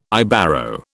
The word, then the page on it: Ibero: British English